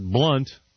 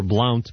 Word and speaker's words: Blount: So here's Major General Buford Blount, or Blount, B-L-O-U-N-T, depending on how you'd like to pronounce it.